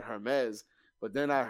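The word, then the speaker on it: Hermès: It's not actually pronounced Hermes. It's Hermes.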